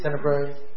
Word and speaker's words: Tenebrae: Tenebrae, which I think would be very moving.